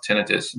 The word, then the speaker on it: tinnitus: tinnitus, otherwise pronounced tinnitus, and so forth.